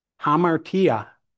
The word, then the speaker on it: hamartia: It is significant to note that the noun form of the word sin, how is it pronounced? Hamartia, hamartia, appears more than 40 times in the passages that address sanctification.